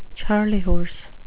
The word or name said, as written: charley horse